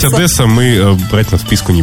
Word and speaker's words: Одесса: Приезжайте, только не забывайте о том, что в Одессе правильно говорить Одесса, а не Одесса.